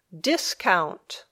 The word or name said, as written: discount